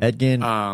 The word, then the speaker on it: Edgin: So Elgin's character, again, don't know if we're pronouncing it wrong. – Edgin, Edgin, whatever. – Edgin, sorry. I threw an L in there.